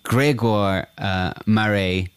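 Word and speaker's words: Grégoire Maret: But yeah, I mean, Gregoire Marais, is that how you pronounce it, Brian? – That is it.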